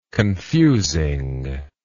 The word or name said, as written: confusing